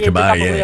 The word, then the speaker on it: Caballé: Yeah, Caballé. Yeah, pronounced Y.